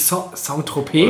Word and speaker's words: Saint-Tropez: St. Tropez. – Heißt das nicht Saint-Tropez? – Oder Saint-Tropez, falsch ausgesprochen.